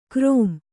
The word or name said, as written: ಗರಂ